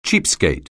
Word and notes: cheapskate: anglais

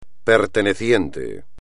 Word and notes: perteneciente: espagnol

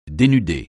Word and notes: dénuder: français

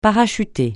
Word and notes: parachuter: francais

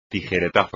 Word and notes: tijeretazo: espagnol

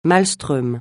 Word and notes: maelström: francais

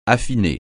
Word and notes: affiner: French

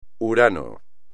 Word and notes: Urano: espagnol

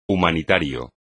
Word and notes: humanitario: espagnol

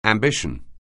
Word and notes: ambition: anglais

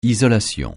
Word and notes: isolation: francais

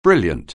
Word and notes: brilliant: anglais

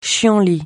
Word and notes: chienlit: français

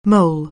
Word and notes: mole: anglais

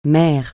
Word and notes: mère: francais

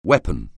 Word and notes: weapon: anglais

